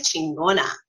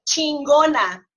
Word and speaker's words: chingona: You know, we're Mexican, and he says, my mom is chingona, you know, meaning like badass, you know? – Oh, is that what that means?